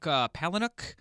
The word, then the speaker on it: Palahniuk: It's written by Chuck Palahniuk. – Yeah. – Am I pronouncing that right? I have no idea. – I think so. I've heard it said that way.